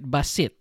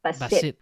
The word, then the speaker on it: basit: And in Ilocano, let me know if I'm saying it right, basit. – That's correct, yeah. Basit. So there's like a, it's kind of hard in the middle right there, basit.